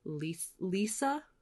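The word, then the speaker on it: Liese: But yeah, I got to meet Allie Hazelwood and Chloe Lise, which I found out recently that her last name is pronounced Lisa. – Mm-hmm. – Lisa.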